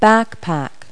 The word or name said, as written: backpack